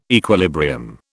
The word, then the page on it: equilibrium: British English